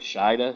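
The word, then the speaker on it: Shida: And then, am I pronouncing it right? Sheeta. – Yeah.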